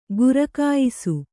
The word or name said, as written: ಘರಖಾಯಿಸು